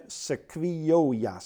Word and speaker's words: sequoia: Het schijnt zo te zijn dat in het noorden van Californië, daar staan oeroude sequoia. Zeg ik dat goed? Het is een boom. Sequoia? Oké.